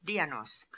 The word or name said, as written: Dionosk